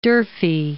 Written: D'Urfey

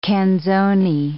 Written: canzoni

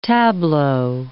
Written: tableau

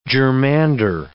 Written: germander

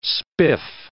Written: spiff

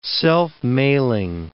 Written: self-mailing

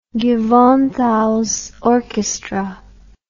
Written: gewandhaus orchestra

